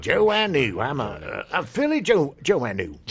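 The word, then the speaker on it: Joanou: Sure, and a lot of you probably have been pronouncing it Phil Joannou, as I have been for the last 40 years. – Yeah. – Not knowing that it was Joannou.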